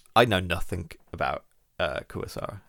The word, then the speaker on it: Kurosawa: So Kurosaga. That's who we're dealing with today. Um, apps, Kurosawa. Kurosawa. Did I say it wrong? I'm going to say so many names wrong. – Akira Kurosawa.